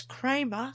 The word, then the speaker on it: Cramer: My name is Linda with a Y, L-Y-N-D-A, and my surname is Cramer, C-R-A-M-E-R. So put me into Google, you find my links.